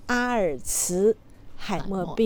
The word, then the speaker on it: 阿尔茨海默: 因为他是德国人，所以我们按照德语发音来翻译这个病名，所以读作阿尔茨海默病。